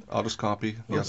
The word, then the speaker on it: autoscopy: So you talk about a condition in the book called autoscopy, is it? Is that, yeah, how you pronounce it? Autoscopy? – Autoscopy, yeah, something like that.